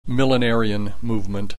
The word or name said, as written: millenarian movement